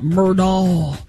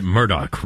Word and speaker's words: Murdaugh: They pronounced it Murdaugh, right, down there? – Yeah. – The family pronounced it Murdaugh, but everybody else was Murdaugh. – I prefer Murdaugh. Because I think you can kind of get into the red and the blue. – Well, we go Murdaugh.